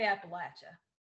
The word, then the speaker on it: Appalachia: I say Appalachia.